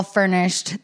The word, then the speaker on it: Fernish: But yeah, being able to move in, get it all furnished this week is just feeling so good. We have, oh my God, let me just shout out this company. The furnishing company, it's called Furnish, but it's spelled F-E-R-N-I-S-H.